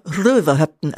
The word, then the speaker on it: Rauhöften: Und neuerdings reisen die Leute in diesen Ort, der übrigens Rauhöften geschrieben wird, aber Röverhöften, also Röverhöften ausgesprochen wird.